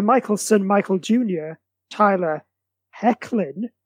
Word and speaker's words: Hoechlin: It's, yeah, so this is Hoechlin.